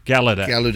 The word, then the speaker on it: Gallaudet: Gallaudet. Gallaudet. It's not Gallu, it's Gala.